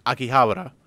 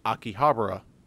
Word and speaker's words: Akihabara: the they asked her to pronounce the words like like Akihabara. Uh, that's the way you would pronounce it correctly in Japanese, but in English you would pronounce it Akihabara.